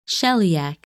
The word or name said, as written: Sheliak